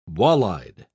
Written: walleyed

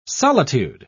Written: solitude

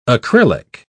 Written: acrylic

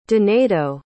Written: donaido

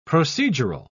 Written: procedural

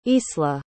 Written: isla

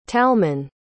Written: talmon

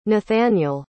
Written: nathaniel

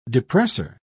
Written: depressor

